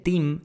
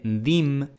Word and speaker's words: theme: team en lugar de theme, hay gente que dice dim en lugar de theme, y todo esto es lo mismo.